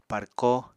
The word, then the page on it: párkó: apatani